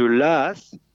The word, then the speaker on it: Laas: Et le village de Laas, ça s'écrit L-A-A-S, dont le maire se demandait un peu comment redonner du dynamisme à son village.